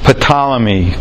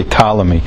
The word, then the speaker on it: Ptolemy: Ptolemy. That's not how you say it, so you say Ptolemy. But it's a PT.